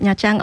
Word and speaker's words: Nha Trang: Nee, also wir kommen aus der Küstenstadt Nha Trang, also es wird Nha Trang ausgesprochen.